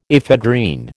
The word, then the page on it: ephedrine: American English